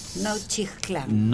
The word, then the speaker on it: Nochixtlán: La X se pronuncia J, Nochixtlán. – Nochixtlán, gracias, ahí está, tiene usted razón, Oaxaca, como México mismo, ¿no?